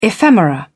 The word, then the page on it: ephemera: British English